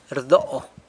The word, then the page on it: rdo: sanlucasquiavini